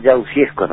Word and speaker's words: Yagudexco: ¿Cómo pronuncié su apellido? Porque escribirlo es difícil y pronunciarlo peor. – No, se entiende. Oscar Yagudexco, ¿no es cierto? Se entiende. – Yagudexco, ahí está, Yagudexco.